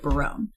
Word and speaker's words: Berowyne: B-E-R-O-W-Y-N-E, which is how they spelled it with ours, and they pronounced it Barone. – Yeah, I've heard Barone. – That's a super Welsh way of spelling it.